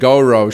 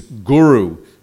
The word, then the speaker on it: Guru: He was singing Gaura Shri. – On zpíval Gaura Shri. – Yeah. Prabhupada stopped him. He said, "It is Guru, not Guru."